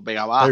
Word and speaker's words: Vega Baja: O sea, cuando dice de Vega Baja y lo dijeron perfecto, Vega Baja.